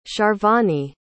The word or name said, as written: sharvani